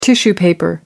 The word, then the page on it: tissue paper: British English